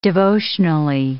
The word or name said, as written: devotionally